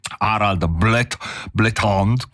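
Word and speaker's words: Harald Blåtand: E perché ho ricordato Harald Blethond, come forse sarebbe la possibile pronuncia del nome in norvegese,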